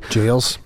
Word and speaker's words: Giles: Shout out Lachlan Yiles. I think I pronounced that correctly. – Giles.